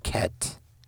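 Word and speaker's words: Ket (ח): So the Hebrew letter Ket, it's spelled, like if you read it in English, you might say Heth, H-E-T-H, or Hebrew might be C-H-E-T-H, but it is pronounced Ket.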